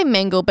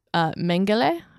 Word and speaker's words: Mengele: I say Mengel, but I guess the German pronunciation is Mengele, I think.